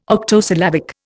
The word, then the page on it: octosyllabic: Indian